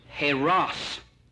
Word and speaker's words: eros: You got the first one, E-R-O-S. And that's pronounced with an H sound, heros.